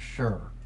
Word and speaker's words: Shur: I think it's Shur. I think is how it's pronounced. S-H-U-R. That's how it's spelled.